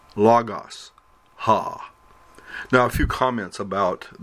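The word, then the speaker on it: ha (ὁ): The nouns in the right-hand column are followed by the form omicron with a rough breathing. This is actually the masculine form of the word the, and is used to indicate the gender of the noun. Pronounce it ha.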